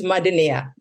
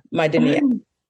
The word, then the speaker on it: Madnea: I really appreciate when people try, like if you mispronounce my name, that's fine. But yeah, no Maddie, like Madnea, Mada Noodle, you know, whatever you want to call me, but just try. But it's Madnea, Madnea.